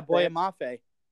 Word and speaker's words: Boye Mafe: Boye Mafe? – Oh, yeah, Boye Mafe.